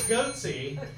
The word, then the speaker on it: Goethe: It is Goethe.